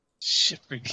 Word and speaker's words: Schipperkes: I know I'm saying that way wrong. I'm gonna hear the actual pronunciation of it. I'm gonna be like, "Oh, I'm an idiot." – I think you're close. Yeah. It's either Schipperkes or Schipperkes. – You're saying it perfectly.